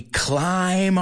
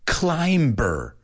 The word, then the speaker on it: climb: Do you hear that B? Did I say climb? No. It's climb on board. Súbete. Hop on board. The reason I tell you is because I've heard so many people say climber or to climb. But that's not the pronunciation. We don't pronounce it. That B is a silent B, such as bomb, dumb, numb, thumb, right?